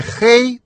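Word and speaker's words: хэй (黑): Прилагательное «хэй» произносится с первым тоном.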